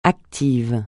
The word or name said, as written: actif